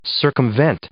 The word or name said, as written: circumvent